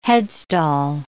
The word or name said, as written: headstall